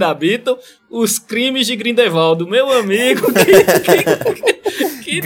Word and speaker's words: Grindelwald: Como é que fala o nome desse cara? Grindelwald, Grindelwald... – Grindelwald. – Grindelwald. É isso mesmo. Grindelwald. eu, eu queria Grindelwaldo.